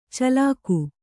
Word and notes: ಚಾಲಾಕು: kannada

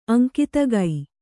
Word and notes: ಅಂಕಿತಗೈ: kannada

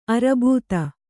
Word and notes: ಅರಬೂತ: Kannada; arbūta

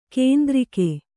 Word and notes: ಕುಂದುರುಕ: Kannada

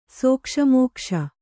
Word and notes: ಸೋಕ್ಷಮೋಕ್ಷ: sōkṣa mōkṣa

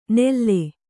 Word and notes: ನೆಲ್ಲೆ: kannada

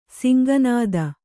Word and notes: ಸಿಂಗನಾದ: singa nāda

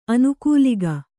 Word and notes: ಆನುಕೂಲಿಕ: ānukūlika

